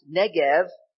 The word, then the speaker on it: Negev: And the Negev, now pronounced Negev, is a real place in the southern part of Israel.